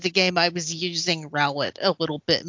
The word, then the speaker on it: Rowlet: That's a weird way to pronounce Rowlet. – You just gotta roll it a little bit more. – Rowlet.